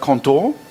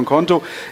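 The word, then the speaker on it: Qonto: Zu meiner rechten Seite sitzt der Philipp Pohlmann von, ich musste nachfragen, wie es ausgesprochen wird, Konto, ja, Französisch wird es genauso ausgesprochen wie im Deutschen, von Konto.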